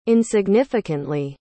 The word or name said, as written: insignificantly